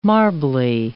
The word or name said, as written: marbly